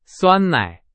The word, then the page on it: 酸奶: Chinese